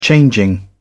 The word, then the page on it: changing: British English